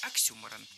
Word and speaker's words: Оксюморон: Оксюморон. – Оксюморон. Ударение в этом слове нужно ставить на второй слог. Оксюморон.